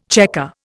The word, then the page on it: chequer: Indian